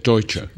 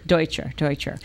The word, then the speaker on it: Deutscher: and distinguished radical historian Isaac Deutscher. Is that how you pronounce it? – Isaac Deutscher. – Deutscher.